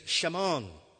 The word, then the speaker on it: Simon: The Hebrew pronunciation is Shamon, which literally means he has heard.